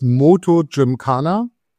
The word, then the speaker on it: MotoGymkhana: Also das Ganze heißt MotoGymkhana. Man kann es tatsächlich so, wie du es ausgesprochen hast, vollkommen korrekt.